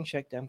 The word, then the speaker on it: Shakdam: And am I pronouncing Shakdam correctly? – Yeah.